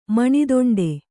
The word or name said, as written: ಮಾನದಂಡ